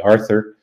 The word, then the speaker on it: Arthur: But of course, with their strong Dutch accents, they couldn't actually pronounce my name. They called me Arthur. My mom still calls me Arthur. She's 90 years old. And to the day he died, my dad never called me Arthur. And in fact, I didn't even pronounce my name correctly. It wasn't until kindergarten that I learned how to say the TH, and the kindergarten teacher put her finger in my mouth finally to get me to put my tongue in the right place.